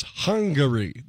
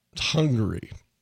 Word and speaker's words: Hungary: He is off to the capital city and it's Hungary. That's three syllables, right? Not hungry like we are sometimes at noon when we start the show. Hungary.